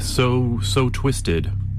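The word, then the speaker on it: Sew Twisted: So Twisted, but it's spelled S-E-W, and Visions Within.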